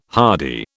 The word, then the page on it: hardy: British English